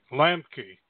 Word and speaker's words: Lambke: Lambke.